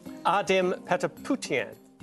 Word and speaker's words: Ardem Patapoutian: Ardem Patapoutian.